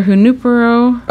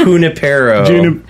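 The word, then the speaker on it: Junípero: Also, Father Junipero. – That was a mispronounced. It's Junipero.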